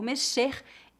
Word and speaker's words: mexer: Nesta lição, você vai aprender como usar o verbo mexer em oito situações diferentes. Vamos começar falando sobre a pronúncia do verbo mexer. A letra X nesta palavra é pronunciada como as letras SH em inglês.